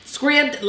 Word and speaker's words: Scribd: They give us the pronunciation. Scribd, like ribbed or fibbed.